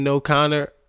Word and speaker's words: O'Connor: Sinead O'Connor. That's how you say it.